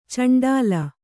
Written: ಚಂಡಾಳ

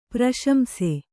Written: ಪ್ರಶಂಸೆ

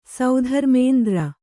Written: ಸೌಧರ್ಮೇಂದ್ರ

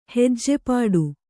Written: ಹೆಜ್ಜೆಪಾಡು

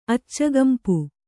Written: ಅಚ್ಚಗೆಂಪು

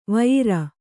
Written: ವೈಯಾರ

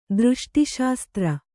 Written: ದೃಷ್ಟಿಶಾಸ್ತ್ರ

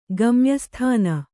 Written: ಗಮ್ಯಸ್ಥಾನ

